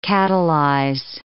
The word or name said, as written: catalyze